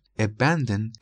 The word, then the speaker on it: abandon: abandon سه سیلابی و استرس سیلاب دومشه. abandon یعنی روی سیلاب دوم ما فشار رو وارد میکنیم موقع تلفظ. abandon.